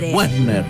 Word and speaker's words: Wagner: Richard Wagner, un compositor alemán, pieza Wagner, clave del romanticismo. – Ah, Wagner. – No, chicos, se escribe con W-A-G-N-E-R. Wagner. – Claro, se pronuncia Wagner.